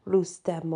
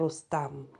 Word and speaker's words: Rustam: Azdiv was slain by the cultural hero Rustem or Rustam, depending on how you pronounce it.